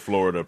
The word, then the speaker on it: Flo Rida: Flo Rida. Spelled Florida, pronounced Flo Rida.